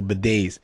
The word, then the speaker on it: bidet: So bidets, but that's all, folks. Bidet. So spelled bidets is spelled bidets, BIDETS, which sounds kind of weird.